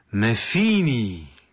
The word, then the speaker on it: Metheny: I że mówi się Metheny.